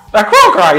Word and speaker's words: Aarakocra: A Aarakocra, yes, that's how you say it, yeah.